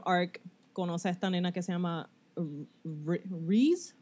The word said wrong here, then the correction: Reze: Reze. Se escribe R-E-Z-E.